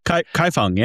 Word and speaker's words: Kaifeng: We're also hoping to go to, and help me with the pronunciation, is it Kaifeng in Henan province? – Kaifeng, yeah, beautiful. – Kaifeng, right.